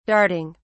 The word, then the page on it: darting: British English